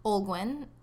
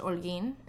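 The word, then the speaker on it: Olguín: Olguin, or in Spanish, Olguin.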